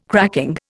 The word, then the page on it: cracking: Indian